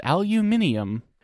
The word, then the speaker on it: aluminium: You agree with me that that is the way that you pronounce the good element. – I was trapped. – The good AL element on the table is called aluminium.